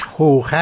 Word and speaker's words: خوخه: در دیوار غربی مسجد النبی صلی الله علیه و آله جایی هست که میگویند یادبود خوخه یا سوراخ ابوبکر است. خواستم استاد پیشوایی درباره پیشینه تاریخی آن توضیح دهند. متشکرم. – تلفظ صحیحش خوخه است.